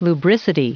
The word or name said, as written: lubricity